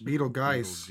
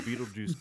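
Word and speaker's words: Betelgeuse: Beetlejuice, Beetlejuice, Beetlegeist. – Beetlejuice. – Yeah. – Well, originally the Beetlejuice character was spelled the same way as Beetlegeist, so and since this is a paranormal podcast, we're going with Beetlejuice, which is a huge red star in the Orion constellation that makes up part of his belt, and it may explode soon.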